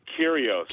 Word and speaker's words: Kyrgios: Nick Kyrgios.